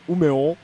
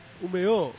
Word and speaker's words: Umeå: En realidad no se dice Umeå, lo siento, siento joderte las pronunciaciones hoy, pero se llama Umeå. – Umeå. – Sí, la, esa A con una O. Umeå. Se llama Umeå. Por cierto, que Umeå tocaron Refuse el otro día, ¿eh? – Efectivamente, no cobraron 150.000 euros como el Primavera.